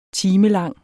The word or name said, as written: timelang